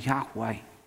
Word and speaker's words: Yahweh: But to help us pronounce it, two vowels from the Hebrew word Adonai were taken, and we make the name Yahweh.